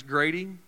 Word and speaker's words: Grady: My name is Grady, which rhymes with shady.